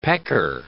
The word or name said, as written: pecker